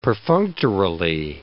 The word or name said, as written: perfunctorily